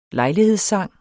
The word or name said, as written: LEJLIGHEDSSANGENE